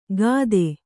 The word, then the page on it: ಗಾದೆ: gāde